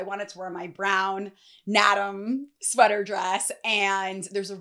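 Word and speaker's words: Naadam: All right, so this is a brown ribbed Nedam. I believe I'm pronouncing that right. I actually wanna look it up. You see, I try. Nadam. So I didn't pronounce it right.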